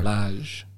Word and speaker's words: Lage: You see, that's how you say his name, I isn't believe it? so. Lage.